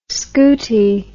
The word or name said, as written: scootie